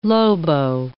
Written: lobo

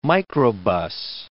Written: microbus

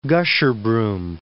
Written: Gasherbrum

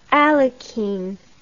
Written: alekhine

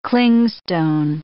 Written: clingstone

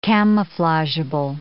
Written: camouflageable

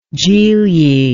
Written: gigli

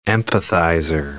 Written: empathizer

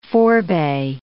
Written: forebay

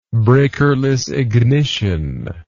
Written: breakerless ignition

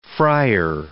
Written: fryer

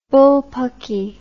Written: bullpucky

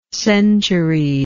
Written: centuried

